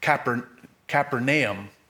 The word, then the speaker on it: Capernaum: Now, notice the name Capernaum. It's actually, we butcher that. If we could really hear how they pronounce it, it's more like Capernaum.